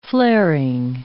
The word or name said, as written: flaring